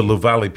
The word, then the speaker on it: Luvale: Yeah, so, so the uh, language of the um, of the Luvale people if I'm saying that correctly.